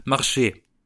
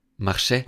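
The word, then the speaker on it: marcher: Zum Beispiel, wenn ich sage, ich, ich laufe, also laufen auf Französisch heißt marché. Marché. Man schreibt es, man schreibt es mit, am Ende mit ER. Aber das R, das spricht man nicht, man macht marché.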